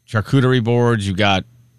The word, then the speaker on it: charcuterie: It's charcuterie.